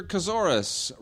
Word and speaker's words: Kazoris: Peter Kazoris, rhymes with thesaurus in Toronto, says, quick email on the topic of actors.